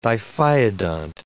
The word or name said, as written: diphyodont